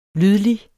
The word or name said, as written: lydlig